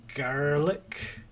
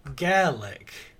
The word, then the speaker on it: Garelick: Why are you saying it in a sort of his name is spelled G-A-R-E-L-I-C-K. Garlick. – Okay. – Oh, right. – It's not I want to say garlic. But it's not garlic.